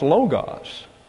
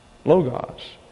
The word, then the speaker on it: Logos: That's L-O-G-O-S. And in modern Greek, that's pronounced Logos. Logos. But in Koine Greek, it's Logos. Now, why is that? Because this is not an omega, but an omicron.